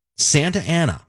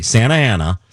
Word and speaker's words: Santa Ana: Santa Ana. I'm sorry. Let me say it correctly. Santa Ana.